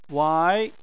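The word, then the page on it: wai: wai2; tone 2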